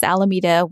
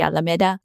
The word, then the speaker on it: Alameda: And being in California, a lot of the city names, for example, Alameda, which is the city I live in, it's pronounced Alameda when it should be Alameda.